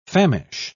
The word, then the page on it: famish: British English